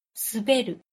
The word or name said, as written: suberu